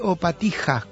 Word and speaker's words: Opatija: Se escribe Opatija con J, J. Y siempre que en este idioma o en esa zona del mundo veamos J, es como una doble I, se ve un poquito largo, ¿no?